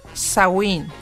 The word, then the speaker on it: Samhain: realmente la palabra es Samhain o Samhain y se pronuncia Samhain, que quiere decir literalmente el fin de verano.